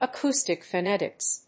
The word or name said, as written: acoustic phonetics